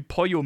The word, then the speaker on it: Pollo: Also ein Beispiel: ein Wort wie Pollo mit Doppel-L, im Spanischen spricht man Doppel-L-J, im Argentinischen spricht man Sch und im Mexikanischen wieder anders.